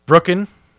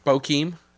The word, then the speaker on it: Bokeem: And I went with, I butcher this name every time, Brooken. – Bokeem.